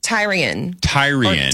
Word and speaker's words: Tyrion: I always think like Ty. I think Ty first, and then I say the name, because if I don't, I will say that. – Yep. – Tyrion.